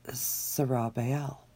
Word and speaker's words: Zerubbabel: And so through the years, when I saw this name in Scripture, I was thinking Zerubbabel, Zerubbabel, or however you want to say it. It's Zerubbabel. And he said, this is Zerubbabel. That's how he pronounced his name.